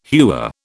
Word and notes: hewer: British English

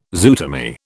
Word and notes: zootomy: British English

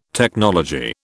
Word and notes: technology: British English

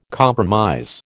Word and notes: compromise: American English

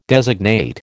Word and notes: designate: American English

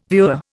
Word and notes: viewer: Indian

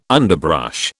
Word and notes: underbrush: British English

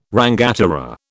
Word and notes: rangatira: British English

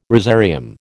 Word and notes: rosarium: American English

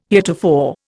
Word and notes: heretofore: Indian

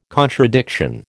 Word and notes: contradiction: American English

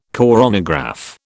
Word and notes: coronagraph: British English

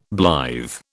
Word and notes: blithe: British English

